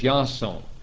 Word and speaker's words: Iason: No, I said Yason. It was pronounced as Yason. Now in the Greek, it was the letters Iota Alpha Sigma Omega Nu, equal to our English letters I-A-S-O-N, with a long O sound and with an A sound on the A.